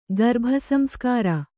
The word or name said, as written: ಗರ್ಭಸಂಸ್ಕಾರ